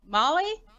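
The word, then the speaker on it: Mahle: Molly? Just Molly, M-O-L-L-Y, you pronounce it? – Yeah, it sounds like Molly.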